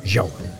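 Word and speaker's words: João: Men det här var Jao, J-A-U-A-O, med en sån där grej över. Hur uttalar man det? Jao. Jao.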